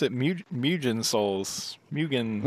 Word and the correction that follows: Mugen: Well, you said you're still like inserting this extra ya sound that isn't in there. – Yeah. – It's Mugen Souls.